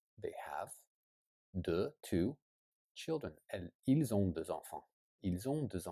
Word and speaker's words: ils: Ils ont deux enfants. Ils. Okay. So if it's alone, you don't pronounce the S, but if it's connected, then it becomes a Z.